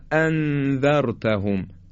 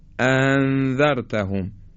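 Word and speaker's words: أأنذرتهم: به عنوان مثال وقتی ما میگیم اانذرتهم در سوره مبارکه بقره دو تا همزه داریم، وقتی میگیم ا این یک همزه است، وقتی دو تا همزه رو تلفظ میکنیم باید بگیم اانذرتهم ا انذرتهم. حالا اگه یکی از اینا رو خوب نگیم به این شکل میشه انذرتهم، یه چیزی شبیه هم میشه انذرتهم، یا اینکه به همدیگه وز کنیم انذرتهم، باز این صحیح نیست.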